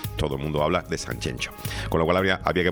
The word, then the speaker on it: Sanxenxo: en gallego es con doble X, algunos se empeñan en escribirlo con G y con J y pronunciarlo como les dé la gana. Sanxenxo es como se debe decir,